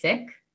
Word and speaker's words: Sikh: So the correct pronunciation is Sikh. Mm-hmm. It's Sikh. It's Sikhism. It's Sikhi. It's not Sikh. A Sikh is just, you know, a difference of accent.